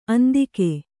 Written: ಅಂದಿಗ

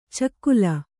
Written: ಚಿಗಿಲ್

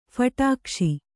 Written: ಫಟಾಕ್ಷಿ

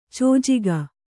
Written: ಚೋಜಿಗ

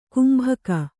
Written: ಗುಂಬಿಗ

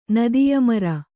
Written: ನದಿಯಮರ

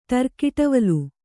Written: ಟರ್ಕಿಟವಲು

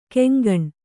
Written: ಕೊಂಕಣೆ

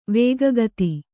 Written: ವೇಗಗತಿ

